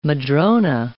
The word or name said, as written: madrona